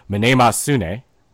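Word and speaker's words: Mnemosyne: called Minemasune. That is, again, mm-hmm. the proper pronunciation in Greek.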